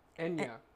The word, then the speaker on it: Ennia: Is her name Elia? Ennia. Enya, like the singer. E-N-N-I-A. – Yeah. – Spelled different, but it's pronounced like the same.